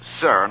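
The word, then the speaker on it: Cyr: Here's a joke sent in by Maurice Sir, and I'm going to spell that because I don't want to mispronounce anyone's name. It's C-Y-R, I believe it's pronounced Sir, of Westport, Connecticut.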